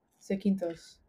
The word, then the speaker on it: Zakynthos: Zakynthos. – Zakynthos.